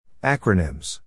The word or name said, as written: acronyms